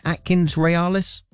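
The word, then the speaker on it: AtkinsRéalis: Is that right, Atkins Realis? – That's perfect.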